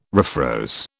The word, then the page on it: refroze: British English